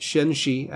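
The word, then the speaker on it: Xinxii: It's spelled X-I-N-X-I-I. I had to look it up. Yes, it is pronounced Shinshi.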